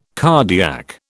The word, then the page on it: cardiac: British English